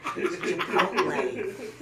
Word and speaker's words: Chipotle: No one can pronounce the E. It's Hispanic, Dad. Chipotle.